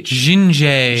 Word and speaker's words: Zsinjeh: I think Zinje. Is that how you think you pronounce it? Z-S-I-N-J-E-H. – Zinje.